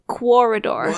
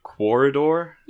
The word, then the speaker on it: Quoridor: Did you say Corridor? – Yep. It's spelled Q-U-O-R-I-D-O-R.